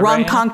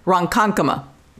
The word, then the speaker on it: Ronkonkoma: This case comes out of Ronkonkoma, New York. I think I'm pronouncing that right. Is that Ronkonkoma. – Right? Ronkonkoma, New York. – It's all in the pronunciation. Ronkonkoma.